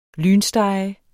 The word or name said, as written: lynstege